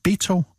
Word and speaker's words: Beto: at vi udtalte det forkert. Og så tænkte jeg, det gør jeg ikke, for jeg synes, jeg havde hørt det på amerikansk TV, og nu har jeg undersøgt det. Det er Beto, og ikke Beto.